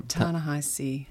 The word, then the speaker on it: Ta-Nehisi: Ta-Nehisi uh, Coates.